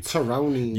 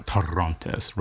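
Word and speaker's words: Torrontés: Toronis. – You got some of that? Yeah, nah. Torontis. Roll your R. – Torontis.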